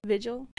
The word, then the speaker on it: Vigil: Vigil.